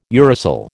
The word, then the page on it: uracil: American English